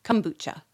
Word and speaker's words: kombucha: Yes, I looked up how to pronounce it. And I said it wrong anyway. It's kombucha.